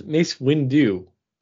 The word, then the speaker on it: Mace Windu: Mace Windu. – Why do you keep on putting an L in there? It's Mace Windu.